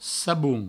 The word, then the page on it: sabon: saboun